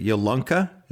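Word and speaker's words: Yalunka: Is it Yolunka? Is that the language that you did that translation for? – It's amazing. It's like you're a native speaker.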